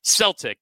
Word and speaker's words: Celtic: look at this idiot calling it Celtic, but that is how they pronounce it in Scotland.